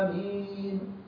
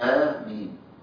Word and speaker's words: آمين: من قال إن آية تتمد ست حركات؟ الجمهور على إنها تنطق آ. ده بيسموه مد بدل. المد فيه حركتين بس مش ست. فنقول آمين. مد مين زي ما أنت عايز. معاك اثنين أربعة ست. يبقى تنطق آمين.